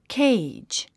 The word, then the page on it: cage: kéidʒ; ケェイジ